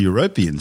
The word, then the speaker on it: European: And like Earth's mid-ocean ridges, it's possible the European seafloor, and yes, that's how you say it, may host volcanoes, which could then provide more energy and nutrients for a potential biosphere.